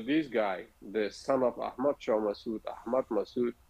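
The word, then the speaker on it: Ahmad Shah Massoud: And isn't it Ahmed Sarah Massad? Is that the war hero? Did I say that right? – Yeah. That was Ahmed Shah Massoud.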